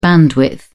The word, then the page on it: bandwidth: British English